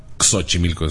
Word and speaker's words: Xochimilco: Xochimilco es la pronunciación.